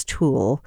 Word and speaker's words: TEWL: Uh, transepidermal water loss, or TEWL, what we pronounce TOOL, at a minimum.